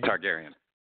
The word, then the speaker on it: Targaryen: Targaryen. – Targaryen.